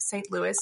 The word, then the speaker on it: St. Louis: and it is pronounced St. Louis.